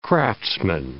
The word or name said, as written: craftsman